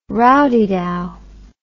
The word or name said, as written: rowdydow